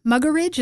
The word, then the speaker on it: Muggeridge: You mentioned a Western journalist, Malcolm Muggeridge.